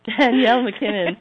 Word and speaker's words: Danielle MacKinnon: Now today I'm really super excited to welcome our very special guest, Danielle MacKinnon. Am I saying that right? Oops. Danielle. I muted you, Danielle. Sorry. God. Danielle MacKinnon.